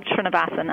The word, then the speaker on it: Srinivasan: You actually did very well, Sherry, I'm sorry to have such a long name, but it's pronounced exactly as it's spelled, Srinivasan, and I think you did a pretty nice job.